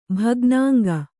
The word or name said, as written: ಭಗ್ನಾಂಗ